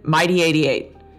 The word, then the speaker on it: MyD88: They're TRIF and MIDI88. So TRIF is spelled T-R-I-F and MIDI88. – M-Y-D-8-8.